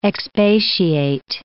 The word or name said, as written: expatiate